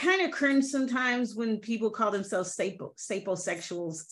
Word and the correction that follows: sapiosexual: sap, sapiosexual. Am I saying this right? – Yes, you're saying it right.